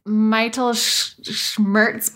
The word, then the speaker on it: Mittelschmerz: It's Mittelschmerz, but the schmerz is supposed to be like square, squirt.